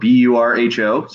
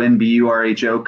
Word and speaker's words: Burho: So my last name is spelled Burho. So it's Brandon Burho Coaching.